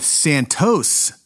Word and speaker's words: Santos: First of all, this guy's first name is pronounced with or without the S at the end all over the place. After waffling on this pronunciation for a while, until someone can persuade me otherwise, I'm going with Santos Traficante because that's what he told the House Select Committee on Assassinations his name was, as noted in the official transcript from when he testified.